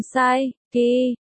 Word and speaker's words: Psyche: Tên nàng là Psyche, phát âm giống Psyche, có nghĩa là tâm hồn.